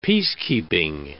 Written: peacekeeping

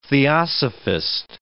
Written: theosophist

